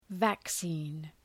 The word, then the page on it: vaccine: væk’si:n